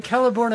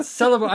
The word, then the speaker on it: Celeborn: How is it Celeborn and not Celeborn? I've been reading it as Celeborn this entire time. – Well, that's because... No. Tolkien is... The C's are always hard C's.